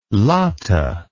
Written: latah

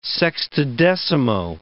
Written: sextodecimo

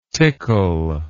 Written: tickell

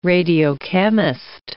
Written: radiochemist

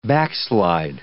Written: backslide

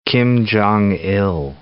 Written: Kim Jong Il

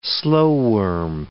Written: slowworm

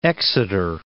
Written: Exeter

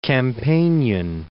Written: Campanian

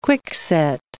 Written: quickset

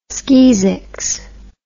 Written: skeezix

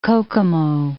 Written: Kokomo

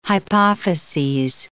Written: hypophyses

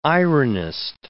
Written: ironist

